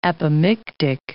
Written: apomictic

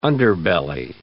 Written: underbelly

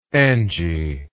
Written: Angie

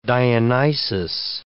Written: Dionysus

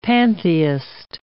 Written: pantheist

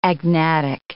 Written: agnatic

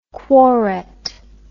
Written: quorate

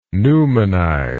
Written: newmanize

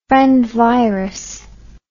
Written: friend virus

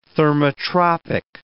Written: thermotropic

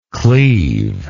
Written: cleavingly

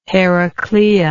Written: heraclea

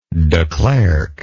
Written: de Klerk

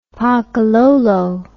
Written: pakalolo

